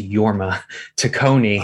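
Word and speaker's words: Jorma Taccone: It's actually pronounced Yorma Tacone.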